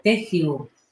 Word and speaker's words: Tehu: La palabra es Tehyu, T-E-H-U, se pronuncia Tehyu.